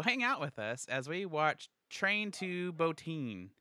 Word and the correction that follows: Busan: I said that wrong. – Or Busan. – Busan.